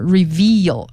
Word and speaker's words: reveal: 好，那么再来呢就是 reveal， R-E-V-E-A-L 这个单词的发音是 veal，这个 V 后边是长的 E，如果你发的时间不够长，听起来就像 review，就变成了 R-E-V-I-E-W。